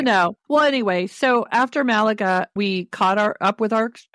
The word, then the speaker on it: Malaga: Malaga.